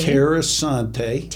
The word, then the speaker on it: Tierra Santé: Tierra Santé. – Tierra Santé is the English version. I'm wanting to roll my R's because I'm Hispanic.